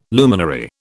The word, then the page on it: luminary: British English